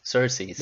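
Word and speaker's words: Xerces: X-E-R-C ES, Cerseys.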